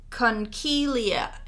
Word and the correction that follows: Conchiglie: a conchilia. Conchiglie. Conchiglie, which is the seashell pasta.